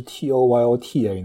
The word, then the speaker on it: Toyota: 如果是 T O Y O T A 呢，就更容易会读成 Toyota，它可能稍微清楚一点。